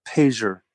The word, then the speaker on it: Payseur: Okay, well, look up the word pager. It's spelled P-A-Y-S-E-U-R. Pager. Pager family. – How do you spell it again? – P-A-Y-S-E-U-R.